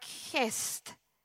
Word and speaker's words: chesed: The Hebrew word there for loving kindness, it's pronounced something like chesed, calls, it means love and loyalty, unswerving love.